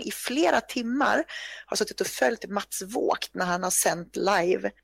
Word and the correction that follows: Vogt: att det uttalas Mats Vogt. Ja, det är klart det är tyska.